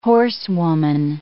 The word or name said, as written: horsewoman